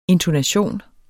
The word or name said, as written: INTONATIONEN